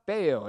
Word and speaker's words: Bello: spelled B-E-L-L-O. So looks like Bello, pronounced Bayo, is going to be going for the Boston Red Sox.